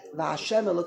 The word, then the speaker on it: Hashem: No, this, that, Hashem, that's indeed how we're going to pronounce it.